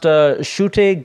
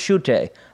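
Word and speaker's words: Ncuti: But the new guy's name, it's a spelling that I was unfamiliar with, but I believe it's actually pronounced Shute Gatwa, I believe is how you say it. And I would not have gotten to Shute, because it starts with an N. I would have guessed Nukute or something like that.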